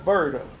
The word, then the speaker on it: Verda: About 1 mile from Everts was called Verda, a little community. – How do you spell that? – V-E-R-D-A, Verda, Kentucky.